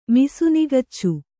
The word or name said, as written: ಮಿಸುನಿಗಚ್ಚು